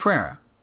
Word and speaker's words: Pereira: And Pereira told the story. Am I saying that right? – Mike Pereira. Yeah.